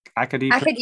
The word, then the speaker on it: Acadie: It's a first time show in the Maritimes, Acadia Pro. – Acadie Pro. Yeah, it's very French.